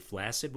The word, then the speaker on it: flaccid: The word flaccid comes from Latin, but the Latin word had a KS sound in the middle, not an S sound. In fact, most words in English that are spelled with two C's in the middle, like flaccid, are pronounced with a KS.